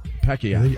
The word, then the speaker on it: Pacquiao: Right, I say it the way it's supposed to be said. Right. Pacquiao. – That's how they say it. Pacquiao.